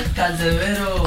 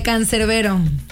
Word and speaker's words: Cancerbero: Cancerebro. Cancerbero. – Cancerbero. – Así tal cual. Uno queriendo verse el gringa, hombre. De Cancerbero.